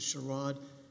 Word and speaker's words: Sherrod: May it please the court on behalf of the appellant and plaintiff, Kenesha Sherrod. That's the way she pronounces her name, with the accent on the second syllable.